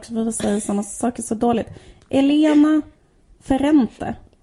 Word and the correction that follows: Ferrante: Uh, eh, Ferrante.